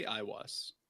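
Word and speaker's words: Aiwass: he had, is it pronounced Iowas or Aowas? – I say Iowas.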